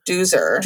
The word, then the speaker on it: Doozer: And then Jennifer said are we pronouncing it dozer or doozer? – Doozer. – Doozer. – Doozer, because it's like rhymes with loser.